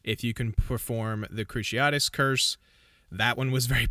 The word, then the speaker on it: Cruciatus: Cruciatus? How do you say this? – Cruciatus. – Cruciatus, okay. Cruciatus curse, which is basically just a torturing spell, curse.